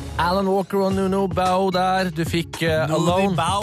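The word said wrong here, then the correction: Noonie Bao: Nuno Bao. Du klarer ikke uttale. Er det... – Ja, jeg synes det litt for voldsomt. – Nuni Bao. – No Bao. – Nei, Nuni. – Nuni. – Bao.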